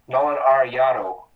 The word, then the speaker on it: Arenado: Well, because in Mexican Spanish, the E is pronounced like an A, so you make Arenado. And and I think it's a Hispanic name, so I'm just trying to pronounce it correctly.